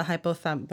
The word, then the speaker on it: hypothalamus: If he underwent a brain study, he would have probably been identified as having an enlarged hypothalamus.